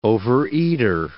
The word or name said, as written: overeater